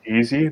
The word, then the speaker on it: Eze: Is it Eze? – I pronounce it easy. That's just because, you know, I bought him like 2 years ago in FIFA career mode, and I was like, oh, that was easy.